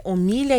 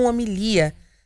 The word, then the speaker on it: homilia: Quero deixar um abraço aqui para o Ricardo Moreno, que faz uma correção aqui na minha pronúncia referente à visita do Papa, dizendo que o nome, na verdade, é homilia e não homilia.